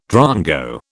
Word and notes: drongo: British English

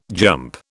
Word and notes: jump: British English

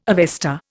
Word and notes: Avesta: Indian

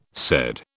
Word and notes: said: British English

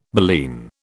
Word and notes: baleen: British English

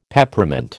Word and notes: Peppermint: American English

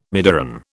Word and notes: midiron: British English

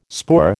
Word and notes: spoor: American English

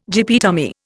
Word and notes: gyppy tummy: Indian

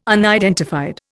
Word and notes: unidentified: Indian

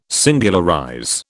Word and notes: singularize: British English

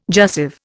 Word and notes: jussive: Indian

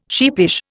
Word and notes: sheepish: Indian

